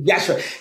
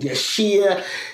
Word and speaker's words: Yashere: The Yashere, because the I is almost is Yashere, the I is silent. So I dropped the I to help people pronounce it properly, and they still mispronounce it for a long time. I get called Yashir, Yashu, like oh. So I have to make I've got this thing now. I go, it's Yashere. Think Yash. Hooray!